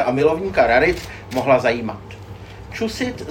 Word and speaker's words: Chusid: V roce 2000 přišel, jak to vyslovujeme, Irvin Chusid? – Chusid, ale Chusid. Jako nejsem si jistá. – Já myslím, že Chusid. – Tak Chusid.